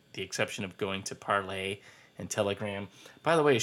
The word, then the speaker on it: Parler: Parlay, spelled Parler, P-A-R-L-E-R. Of course, most people are pronouncing it Parler, so from what I understand, it's Parlay.